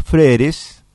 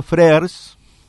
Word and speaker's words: Frères: Bueno, son los fundadores de una empresa de indumentaria que se llama Freres, o si lo pronunciamos en francés, como fue la intención de ellos, Frères, que realmente me han sorprendido por su despliegue y por su capacidad de gestión.